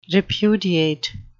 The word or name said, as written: repudiate